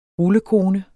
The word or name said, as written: rullekone